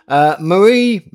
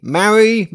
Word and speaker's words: Mhairi: And Maz, just for your information, my name is, and it's pronounced, spelled M-H-A-I-R-I, which I imagine, is that Irish? Possibly. Marie, Mary, Mary, but no one can pronounce it.